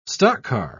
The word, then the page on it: stockcar: British English